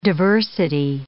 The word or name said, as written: diversity